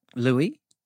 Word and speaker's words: Louis: Here's Lewis. – Louis? – But I've been saying Lewis the whole thing. Been saying his name wrong for the whole of this half of the episode, but here's Louis from Rialto.